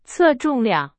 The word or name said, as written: 重量